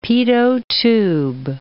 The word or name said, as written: pitot tube